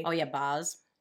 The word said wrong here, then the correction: Baz: It's actually pronounced Baz, we found out.